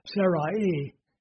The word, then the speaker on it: Sarai: And the word, it would actually be pronounced Sarai in Hebrew.